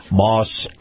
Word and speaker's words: Maus: Moss Nissan. Now, Moss is spelled M-A-U-S.